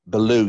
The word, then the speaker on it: Ballew: Is it Ballew? – Yeah. Baloo, yeah, he does actually say that. You pronounce it like Baloo the Bear from Jungle Book as Cap Baloo as well.